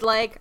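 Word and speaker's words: Legge: Catherine, we've never come down on how we pronounce. Is it? – It's leg. – Okay. Catherine Leg. – I just like to say leggy, but it's leg.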